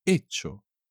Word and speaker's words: hecho: Yo he hecho. Tú has hecho. Él ha hecho. Nosotros hemos hecho. Vosotros habéis hecho. Y ellos, ellas o ustedes han hecho. Y como siempre, la H nunca se pronuncia.